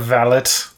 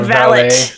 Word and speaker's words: valet: I will accept either pronunciation, the British one where you say the T, or the French way with where the T is silent. – Oh, okay. Is it a valet? – It is a valet.